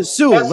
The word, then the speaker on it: Sewell: Shane Sewell? Or Sewell? – Shane Sewell. – Sewell. That's how you pronounce it. Okay. I've got a friend with that surname. – It's S-E-W-E-L-L, isn't it? – Yeah, Sewell.